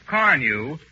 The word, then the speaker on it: Carnu: But don't forget to buy that package of Johnson's Car New this week. It's spelled C-A-R-N-U.